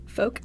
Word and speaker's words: Focht: Folk, it rhymes with Coke.